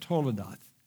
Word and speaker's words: toledoth: It's toledoth is how you say it, toledoth.